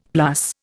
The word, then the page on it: plus: Indian